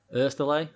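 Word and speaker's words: Oesterle: Is it Westerly? Westel? Oh, I don't even know how to pronounce his name. – Oersteley, I think. – Oersteley. There you go.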